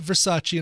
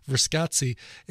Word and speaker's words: Verscazzi: That's really how it's spelled. It's not Versace, and I'm mispronouncing it. It's Verscazzi.